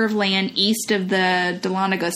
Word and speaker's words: Dahlonega: And then the last one is the Smith House in Dahlonega. Am I saying that right? – Yeah. – Dahlonega, Georgia.